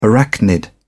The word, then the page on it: arachnid: British English